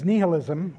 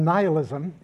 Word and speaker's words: nihilism: One of them is nihilism, or nihilism, if you prefer that pronunciation. That's the one that Merriam-Webster shows as the preferred pronunciation. I like nihilism partly, mostly because the word comes from the Latin word nihil, which means nothing.